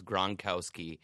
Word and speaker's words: Gronkowski: But I think that this Super Bowl is going to revolve around three syllables, Gronkowski.